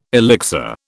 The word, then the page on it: elixir: British English